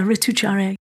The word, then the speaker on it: Ritucharya: And Ayurveda prescribes balancing practices, and I believe they're called Ritucharya. Is that how you say it? – Yeah, Ritucharya.